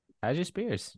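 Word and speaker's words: Tyjae Spears: And also, the big one, he has Tajay Spears. Is that how you pronounce it? – Yes. Tajay Spears.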